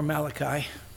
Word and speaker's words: Malachi: Zechariah, right before Malachi. That's how you pronounce it, Malachi. It is not an Italian book of the Old Testament, Malachi. It's Malachi. Malachi 9:14.